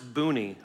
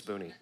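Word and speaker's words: Bani: Jeshua, Benai, Kadmiel, Shabaniah, it's pronounced Booney, I promise. It looks like Bunny, it's Booney.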